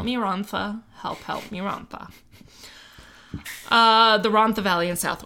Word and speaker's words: Rhondda: That is spelled like Rhondda with an extra D, but apparently it's Rhondda.